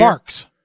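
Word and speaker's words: Barq's: Is it Barg's root beer? – Barks. – Barks. Barks root beer, 44 grams of sugar, and Arizona green tea, 42 grams of sugar.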